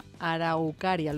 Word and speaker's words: araucaria: El año pasado compré un vivero, una araucaria.